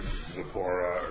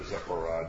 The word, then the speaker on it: Zipporah: marries Zipporah, or Zipporah, depending on what pronunciation you like, and lives for 40 years as a shepherd.